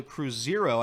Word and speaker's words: Cruzeiro: So, FC Cincinnati is finalizing a loan move for Barreal to Cruzeiro, I believe is how you pronounce that in Brazil.